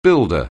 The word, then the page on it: builder: anglais